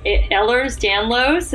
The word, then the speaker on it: Ehlers-Danlos: And since being diagnosed with Ehlers-Danlos, is that how you say it? – Yep.